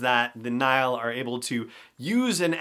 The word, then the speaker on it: Nihil: The Nihil, that's how it's pronounced, like the River Nile, but spelled N I H I L.